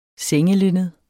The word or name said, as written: sengelinned